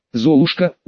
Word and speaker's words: Золушка: Золушек, ударение на последний слог. Кова. Золушка, ударение на последний слог.